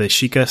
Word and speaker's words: Sheikah: but the Sheikah slate, is that how you say it? – Yeah, Majora's Mask.